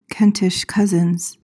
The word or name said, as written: kentish cousins